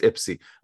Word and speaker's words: Ipsy: Bryant Legend Lepic up there in Ipsy, because I'm from Ipsy and I know how to say it without sounding like a tourist. Ypsilanti. It's not Ypsilanti, folks. It's Ipsy.